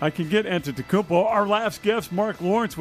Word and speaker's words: Antetokounmpo: The real big question mark is Giannis Antetokounmpo.